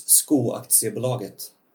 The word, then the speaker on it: Skoaktiebolaget: People from the States tend to call it Squawk, just like. – Yeah, I heard Scopti. – Yes, I've heard that as well. I've heard so many pronunciations of that name. But it's in Swedish, it's Skoaktiebolaget.